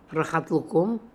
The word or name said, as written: Rahat Lokoum